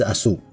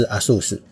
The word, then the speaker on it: Aszú: 不过呢，今天是十二月十号，是这个阿素 day，更正是阿素。哦，不是阿素斯，常常不小心就念成阿素斯，因为阿素斯是那个电脑品牌华硕。但是我们今天不是讲阿素斯，是讲阿素。讲到阿素呢，通常指的就是匈牙利 Tokai 的贵腐甜酒等等。